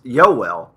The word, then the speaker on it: Yoel: I believe Yoel is pronounced Yoel, as in rhymes with Noel.